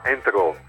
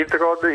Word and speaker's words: Introd: Dunque, il mio comune si chiama Entrò. – Ah, scusa, ok. – Invece di Introd, Introd si legge in italiano, Entrò vuol dire proprio che deriva dal francese Entrò, vuol dire tra le acque, Entre les eaux.